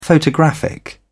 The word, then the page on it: photographic: British English